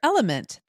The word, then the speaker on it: LMNT: But Sarah, we're welcoming our sponsor, Element. That's spelled L-M-N-T,